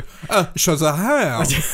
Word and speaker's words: Shazam: That was a really weird pronounce. So, Shazam. It's Shazam.